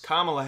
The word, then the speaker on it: Kamala: Kamala Harris. – Nope. – It's her, but you pronounced it wrong. – Kamala Harris. – Kamala Harris is the correct answer.